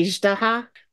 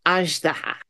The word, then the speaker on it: Azhdaha: In the story, they tend to say Azhdaha, like with a hard G, but it's not. It's Azhdaha. Think of Zhang Li. It's that same Zh.